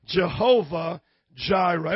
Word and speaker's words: Jehovah Jireh: How do you say that in the Hebrew? Jehovah Jireh.